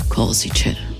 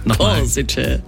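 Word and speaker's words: Košice: 2 neue EC-Züge fahren künftig von Wien bis ins schöne Korsice. – Oh, das hast du auch sehr schön ausgesprochen. – Korsice.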